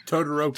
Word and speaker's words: Todoroki: Todoroki. – Todoroki. Todoroki. – Thank you. Todoroki's arc.